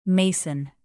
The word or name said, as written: mason